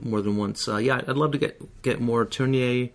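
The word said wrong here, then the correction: Tourneur: and is that how you say it? – Tourneau.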